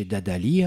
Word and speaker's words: Dadalì: Chez Dadali hein je prononce bien. Hein. – Ah, absolument.